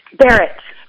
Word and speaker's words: Barrett: Barrett.